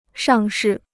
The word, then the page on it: 上市: shàng shì